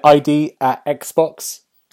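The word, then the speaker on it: ID@Xbox: And yesterday we watched the, I can never say it, is it ID at Xbox? Is that right? – Yes, I said that right? – That's the one.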